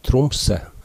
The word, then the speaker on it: Tromsø: Du ser jo at Tromsø i dag ofte uttales Tromsø, altså med en slapp e i slutten.